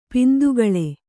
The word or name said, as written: ಪಿಂದುಗಳೆ